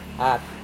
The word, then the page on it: at: Serewen